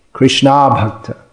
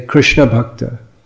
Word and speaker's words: Krishna Abhakta: You have to say it properly. Otherwise, if you say Krishna Bhakta, then it means Chaitanya Mahaprabhu said the symptom of Vaishnava is that he rejects the association of devotees.